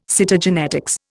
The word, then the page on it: Cytogenetics: Indian